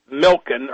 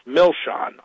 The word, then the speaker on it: Milchan: That first name is spelled A-R-N-O-N. The last name is spelled M-I-L-C-H-A-N. It looks like Milken or Milchen, but it's pronounced Milshon, Arnon Milshon.